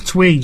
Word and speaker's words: Twi: So not a T, it's a Ch, Twi. – Yes, even though it's spelled with a T-W-I. – It's pronounced Twi.